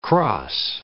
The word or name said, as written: crosse